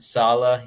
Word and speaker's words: Saleh: Robert Saleh.